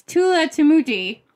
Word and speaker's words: Tulathimutte: Oh, I'm practicing his name, and I don't know if I can say it now. It's Tulatimuti. It's spelled Tulatimutte.